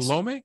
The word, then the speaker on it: Salome: I think Salome? – I think so. – It's like salami? – Yeah.